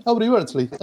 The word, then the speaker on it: Aubrey Beardsley: y entre ellos está un muchacho que nunca sé cómo se pronuncia, que es Aubrey Beardsley, ¿no? – Sí, Aubrey Beardsley, así está perfecto, sí.